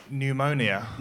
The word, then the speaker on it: pneumonia: pneumonia. – Okay, that's a shit word. – But it's got a silent P for starters.